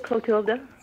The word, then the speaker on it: Clotilda: Is that pronounced Clotilda or Clotida? – I pronounce it Clotilda.